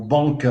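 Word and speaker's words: Bąk: Donc, euh, euh Jacek Bach ou Bank en polonais, hein, c'est méconnaissable la prononciation.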